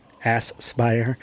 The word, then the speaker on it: Aspyr: Aspyr. Emphasis on the ass.